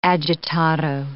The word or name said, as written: agitato